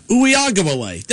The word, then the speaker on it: Uiagalelei: Uiagalelei.